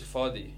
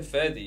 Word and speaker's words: Fadi: No, I know, but it's Fadi. Fadi. – But is that how you pronounce it? Like, he's Lebanese, so the Arabic Fadi, pronouncing... Yeah, Fadi. Fadi. – You didn't say Ferdi, you say Fadi. Fadi. Fadi. It's Fadi, yeah.